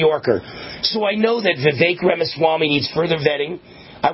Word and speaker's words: Vivek: Vivek, what is it? It rhymes with cake. Vivek, hard to pronounce.